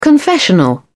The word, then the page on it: confessional: British English